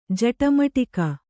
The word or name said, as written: ಜಟಮಟಿಕ